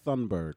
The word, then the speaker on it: Thunberg: Greta Thunberg.